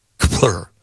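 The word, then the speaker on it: Kpler: I don't know how to pronounce them. Kepler? It's K-P-L-E-R. Kepler.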